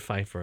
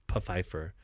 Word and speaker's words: Pfeiffer: And the funeral director, the owner of the funeral home or whatever, his last name is Pfeiffer with a P, but they say Mr. Pfeiffer, and he goes, actually, it's Pfeiffer. You pronounce the P.